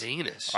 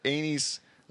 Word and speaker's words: anise: Four times I read it. – I hear anus. – I went on and Googled it. – She doesn't. – The way it's spelled, and it's a anise. It's like the, you know, star anise.